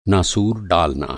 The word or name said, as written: नासूर